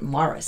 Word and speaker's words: Maurice: Yeah, it's spelled Maurice, but with Irish, they pronounce it Maurice.